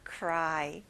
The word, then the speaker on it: cry: R 발음을 정확하게 해주세요. Cry.